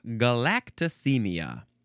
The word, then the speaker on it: galactosemia: Galactosemia. That's how you say it. Galactosemia. So it's the galacto prefix plus S-E-M-I-A.